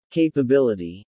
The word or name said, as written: Capability